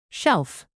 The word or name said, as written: shelf